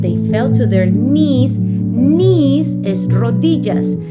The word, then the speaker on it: knees: Knees se escribe K-N-E-E-S. ¿Recuerda esa palabrita? Otra cosa para recordar, amiguito o amiguita, es que cuando tienes la K y la N, la K no suena. Por eso decimos knees. Y le alabaron a Dios. ¿Cómo se dice alabar? Se dice worship. W-O-R-S-H-I-P.